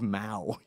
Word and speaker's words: Mao: And he goes, "John is to the left of Mayo." instead of Mao. You pronounced Mao Mayo, something I thought about for 24 hours straight afterwards.